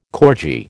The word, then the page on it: corgi: American English